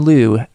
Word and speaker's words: Liew: You can find him on Twitter at Jonathan Liu, LIEW, rhymes with view.